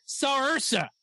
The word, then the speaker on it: Saoirse: It's not spelled like Saoirse. So it's not Saoirse. It's Saoirse is what it is. And it's somehow that's Saoirse because you pronounce the S. Cause of course you want to pronounce the SAO as an SH.